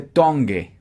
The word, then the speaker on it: tongue: Y ojo, no se dice tongue. Tongue. No, no, no. Tongue. Tongue. Tongue. Como que el g de tongue apenas si suena muy leve.